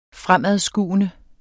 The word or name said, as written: fremadskuende